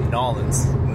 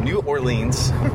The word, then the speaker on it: New Orleans: It's the, I believe you pronounce it Nolens. – I don't. I call it New Orleans.